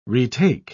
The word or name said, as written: retake